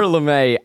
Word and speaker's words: Lame: It's spelled lame, but I can't believe that. – I'm assuming it. I'm like, it's gotta be LeMay. It's gotta be LeMay.